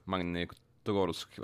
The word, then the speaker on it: Magnitogorsk: Mag heter de Magnitorsk? Magnitogorsk, va? Ja. Bra uttal.